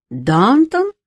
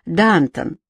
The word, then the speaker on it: Дантон: «Дантон?» — переспросил с удивлением Кант. Он выговаривал «Дантон» с ударением на первом слоге.